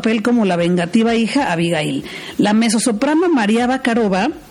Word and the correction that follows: Barakova: Barakova, lo dije mal, pero ya lo corregí, María Barakova y el tenor Sejong Beak.